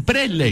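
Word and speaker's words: Preysler: Y de Isabel Preisler, ¿no? – ¿Isabel? – Preisler. – Preisler. – Preisler. – Preisler. – ¿Qué es lo que es? – La gorra te queda tan ridícula. Pero pronuncia bien, Preisler.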